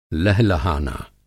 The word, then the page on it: लहलहाना: Hindi